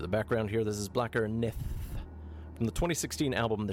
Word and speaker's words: Blakkr Nið: Blacker Nith, and the TH is spelt like an old-school Icelandic thorn.